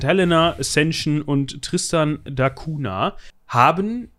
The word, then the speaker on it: Cunha: Spricht man den so aus? C-U-N-H-A? – Ich, denk, ich denke. Cunha? Cunha. – Cunha. Ein Portugiese. Also ne, eine Insel. Und der Name ist portugiesisch.